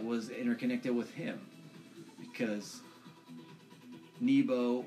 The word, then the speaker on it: Nebo: No, not Nemo. Nebo. – Nebo. – Or Neboo. I think that could also be another pronunciation for it. But N-E-B-O.